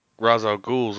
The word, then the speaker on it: Ra's al Ghul: Ra's al Ghul's immortal, but he doesn't have superpowers, though. – Well, first of all, thank you for saying his name correctly.